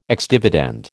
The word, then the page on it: ex dividend: American English